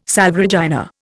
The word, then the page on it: Salve Regina: Indian